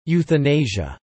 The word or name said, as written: euthanasia